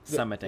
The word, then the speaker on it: summiting: Summiting. – That's what I said, right? – I thought you said summoning. – Oh, no, summiting. I think it's just my Michigan. I didn't pronounce the T's.